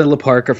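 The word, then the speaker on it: La Parka: Is it pronounced Laparca? Laparca Dos. – Laparca, yes.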